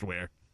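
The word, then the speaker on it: Weare: It's spelled We Are, but it's pronounced Where.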